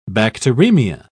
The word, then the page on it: bacteremia: British English